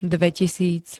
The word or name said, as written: dvetisíc